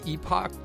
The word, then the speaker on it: epoch: So I've been saying Epic, but that can sound like it's EPIC. – Yeah, that was that. – What do you say? – Well, I say epoch. That's the UK pronunciation. Epic is actually the proper way to say it in US English.